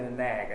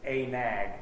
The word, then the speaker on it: anag: There's a spirit behind being a nag. I just want to make clear. This is not, it's not even pronounced a nag. It's Anag.